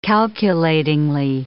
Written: calculatingly

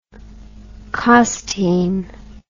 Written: costean

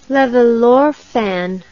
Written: levallorphan

